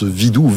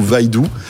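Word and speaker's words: Vidu: Et donc ce Vidou, hein, puisque c'est comme ça qu'Anthony le prononce, Vidou, Vaidou, Gaël, ça t'étonne que les Chinois soient au niveau...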